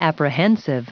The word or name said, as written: apprehensive